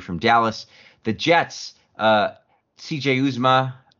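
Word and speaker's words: Uzomah: There's a Bengals fan at the office. I think it's Azuma. – Azuma. Okay, yeah. Azuma.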